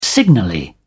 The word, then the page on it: signally: British English